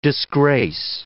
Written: disgrace

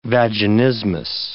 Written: vaginismus

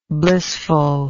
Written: blissfulness

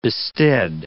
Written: bestead

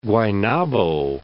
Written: Guaynabo

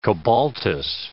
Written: cobaltous

